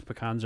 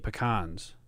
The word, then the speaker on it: pecan: So you could just get pecans. Some people say pecan. – I literally never say this, so like I don't even know how I pronounce it. Pecan, pecan, I could pronounce it either.